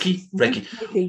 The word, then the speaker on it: Reiki: Reiki.